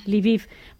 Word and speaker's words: Lviv: ¿Cómo la pronuncias tú, perdón? – Lviv.